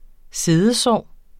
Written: siddesår

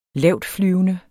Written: lavtflyvende

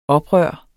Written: oprør

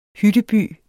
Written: hytteby

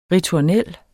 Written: ritornel

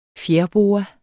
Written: fjerboa

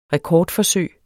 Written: rekordforsøg

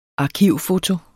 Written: arkivfoto

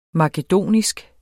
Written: makedonsk

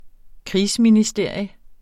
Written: krigsministerium

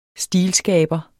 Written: stilskaber

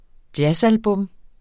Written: jazzalbum

